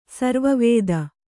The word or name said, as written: ಸರ್ವಾವಧಿ